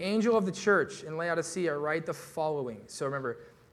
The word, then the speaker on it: Laodicea: And in the last church that we're covering, the church of Laodicea, is how you say it, is covered all of these.